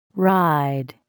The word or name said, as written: ride